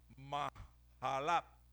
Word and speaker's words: Mahalat: Y dice, para cantar sobre Amahalat o Maalat, como usted lo quiera leer, porque es que esa J en el, esa H en el hebreo se pronuncia como J, entonces es Mahalat.